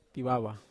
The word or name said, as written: tawawa